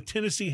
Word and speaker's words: Tennessee: But the Tennessee having the emphasis on the last syllable, it makes it sound better in the song.